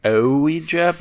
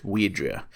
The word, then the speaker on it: Ouija: I mean, I've always kind of maybe overpronounced the Ouija, like Ouija, but I think it's just Ouija.